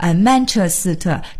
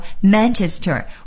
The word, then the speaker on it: Manchester: 文的这个发音和中文的意词啊有的时候非常的接近，比如说 Manchester 就是曼彻斯特，但是同时呢我们也一定要注意，在我们说这个英文词的时候呢，千万不要带上中文的调，也就别说曼彻斯特，这是不行的，我们要说 Manchester。